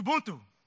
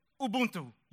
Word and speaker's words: Ubuntu: It's Ubuntu. Ubuntu. U-B-U-N-T-U.